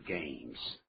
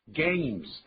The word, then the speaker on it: games: I don't like computer games. Computer, 也就是计算机，我们现在叫电脑。 Games, 代表游戏，千万不要念 games. – 对，念作 games. 那个是一个 A 的音，就是 ABC 的 A 怎么念的？Games，还有要注意啊，重音的问题。